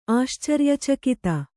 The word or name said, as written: ಆಶ್ಚರ್ಯಚಕಿತ